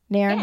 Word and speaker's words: Nairn: Did I say it right? Nairn. – Yes.